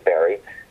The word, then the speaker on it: Barre: And there is a Barry, Vermont, pronounced Barry, like a Wilkes-Barre, Pennsylvania, is also spelled B-A-R-R-E.